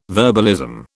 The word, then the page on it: verbalism: British English